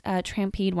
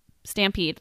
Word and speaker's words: stampede: So this next one, this is the last trampede one I have. Or is that how you say it? Stampede.